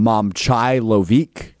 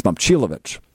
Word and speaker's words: Momcilovic: I believe Linda Cohn called him Momcilovic. It's Momcilovic. But I think Iowa State is terrific.